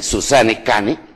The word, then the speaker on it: Susanne Cane: Hay un libro que les recomiendo que es de Susan, con Susan con Z, Susanne, con doble N, Susanne Cane, así como se pronuncia, C-A-N-E, Susanne Cane.